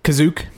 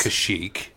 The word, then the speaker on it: Kashyyyk: And then the second one is actually Kazook. – Kazook, or I guess it's actually pronounced by other real people. – Yes. – Kashyyyk. That just sounds wrong.